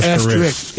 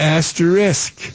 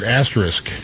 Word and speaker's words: Asterisk: It's not asterisk. It's asterisk. – Yep. – Gotta get that. – Just like the button on your keyboard, asterisk. – That's exactly right. Yep. Anyway, Asterisk